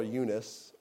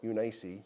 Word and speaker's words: Eunice: You can either call her Eunice or Eunice, depending on how you like to pronounce that.